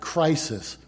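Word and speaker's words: krisis: if I pronounce this right, crisis, with a K, K-R-I-S-I-S, and it means the act of judging, as opposed to the Greek word which is krima, which is the sentence of judging.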